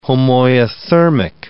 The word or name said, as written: homoiothermic